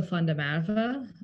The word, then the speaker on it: van der Merwe: Uh, the Afrikaans way is uh, Rachel van der Merwe.